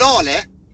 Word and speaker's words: Lale: Hon heter Lale. – Så uttalar man det rätt också. Yes. Jag säger alltid fel, Lale.